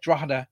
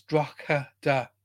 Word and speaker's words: Drogheda: I say that Drogheda, everybody in the planet's going, where? If you look at phonetically, it's Drogheda, but anybody from Ireland, we say Drogheda. We drop a couple of the letters out.